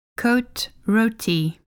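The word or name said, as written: Côte-Rôtie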